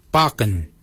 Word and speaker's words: baginy: For beard, we say barken. Barken is spelled B-A-G-I-N-Y.